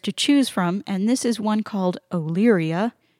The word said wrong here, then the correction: Olearia: Olearia, O-L-E-A-R-I-A. I believe that's how you pronounce it. – That's correct.